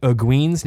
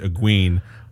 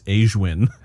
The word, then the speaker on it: Egwene: So I was reading Egwene as Aeshwin.